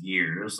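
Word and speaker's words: Gers: So my last name is pronounced Gears, like shifting gears, but there's only four letters and it's G-E-R-S. So I'm everywhere at Tanner Gears on LinkedIn.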